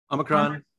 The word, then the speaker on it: Omicron: Omicron.